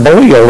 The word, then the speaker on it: Belial: The word Belial there, and it really is pronounced Belial, the word itself means worthless.